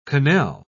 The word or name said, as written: quenelle